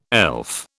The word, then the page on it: elf: British English